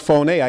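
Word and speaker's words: phoney (φωνή): But the Greek word is phone. It means pronounced. It's pronounced phoney.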